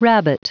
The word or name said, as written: rabbit